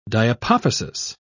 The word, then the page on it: diapophysis: British English